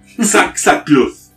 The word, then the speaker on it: Cxaxukluth: En algunos textos se menciona que Xaxax... no, perdón, Xaxacluz. – Se escribe C-X-A-X-U-K-L-U-T-H.